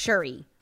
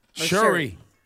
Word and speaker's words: Shuri: So it appears to confirm a major fan theory that's been floating around for months that T'Challa's sister, producer Griff said it's pronounced Shuri. – Shuri.